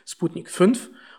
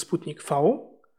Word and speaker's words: Sputnik V: dass halt die Deutschen das falsch aussprechen. Das ist ja halt Sputnik 5 und eigentlich ist es Sputnik V. Von den äh, russischen Medien aus habe ich aber auch immer nur Sputnik V gehört.